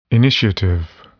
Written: initiative

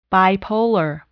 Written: bipolar

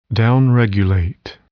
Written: downregulate

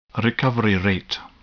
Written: recovery rate

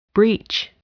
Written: breach

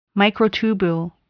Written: microtubule

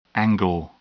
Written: angle

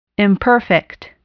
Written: imperfect

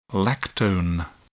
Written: lactone

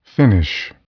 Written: finish

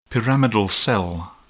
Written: pyramidal cell

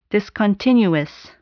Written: discontinuous

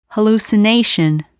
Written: hallucination

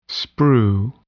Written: sprue

